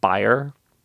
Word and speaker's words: Bayer: They think – but like they think Bayer – it's apparently pronounced Bayer correctly in German.